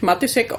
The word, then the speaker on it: Matisek: Ich spreche mich Matisek aus, aber eigentlich gibt es da so einen Hatschek, also wo man das Matisek auch aussprechen kann, wie man will.